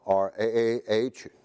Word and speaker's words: raah: And her name comes from a root word, Ra-ah, R-A-A-H, pronounced Ra-ah, and that means to tend the flock or to be a shepherd.